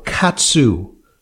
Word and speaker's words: KAATSU: Yeah, it's an interesting question because blood flow restriction training, or KATSU, as it's sometimes referred to, it's spelled K-A-A-T-S-U, is a form of exercise that was developed in Japan by a guy in the 1960s.